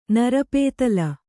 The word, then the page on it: ನರಪೇತಲು: narapētalu